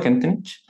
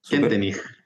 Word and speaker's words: Kentenich: ¿Está bien pronunciado Kentenich? – Kentenich, porque Kentenich. Es alemán.